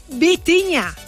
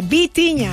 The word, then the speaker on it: Vitinha: Seguimos con Vitinha. No Bocinha, Vitinha.